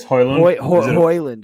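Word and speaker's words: Højlund: But, I mean, you look at players they brought in like Lookman and, you know, this other guy, that Hojlund, that I can't even pronounce his name. – I think it's Hoyland. – Hoyland. Yeah, Hoyland. And, you know, Juan Musso coming in too and being a little shaky.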